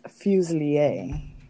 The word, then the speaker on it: Fuselier: It's Fuselier.